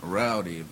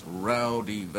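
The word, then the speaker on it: Raudive: And the most complete source book is a book called Breakthrough by Constantine Rowdy. That's actually pronounced Rowdive. It's Latvian.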